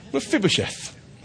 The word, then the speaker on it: Mephibosheth: Mephibosheth. That's how you say it.